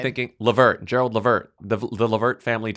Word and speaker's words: Levert: It's pronounced Levert. It's Gerald Levert, not Levert.